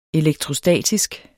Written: elektrostatisk

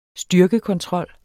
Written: styrkekontrol

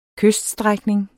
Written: kyststrækning

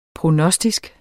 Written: prognostisk

